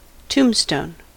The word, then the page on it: tombstone: American English